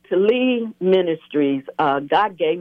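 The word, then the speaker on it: TELI: But more importantly, you have an organization that you are the visionary and founder of, and that's called Telly, and that's T-E-L-I. Am I saying it right? – I pronounce it Telly.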